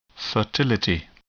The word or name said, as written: fertility